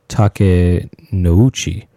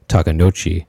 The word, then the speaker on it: Takenouchi: And I guess I wasn't pronouncing that exactly correct before when I was saying Takenouchi, because the reason for that, and Ayumi corrected me on that. And it was and the reason I wasn't pronouncing it, I was pronouncing the O and the U as one syllable,